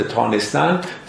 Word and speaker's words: توانستن: کلمه توانستن با تلفظ تانستن فراوان در متون قدیمی و از جمله در مثنوی به کار رفته.